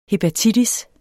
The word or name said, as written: hepatitis